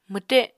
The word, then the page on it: mwte: Apatani